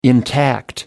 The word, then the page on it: intact: American English